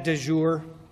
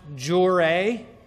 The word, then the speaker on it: de jure: First of all, I was helped to see that I was pronouncing the second option wrong. So de facto, and then I had said de jure. I know that in French, de jure is of the day, but I went that way with the Latin phrase too. It's probably more correctly pronounced jure.